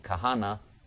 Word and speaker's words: Kahane: And now we go to the great Binyamin Yisrael, who writes, and I quote, why is the Rav's name spelled with an E at the end, Kahana, Kahane, when it is pronounced with an A, Kahana, unquote.